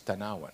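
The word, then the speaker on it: Tanauan: It's Tanawan because it's in our name. It rhymes with number one.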